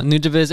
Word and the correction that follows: Nujabes: It's Nujabes.